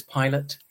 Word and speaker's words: Pilate: And I thought Pilate as in like airline pilot. Well, it's spelled P-I-L-A-T-E, as in Pilate.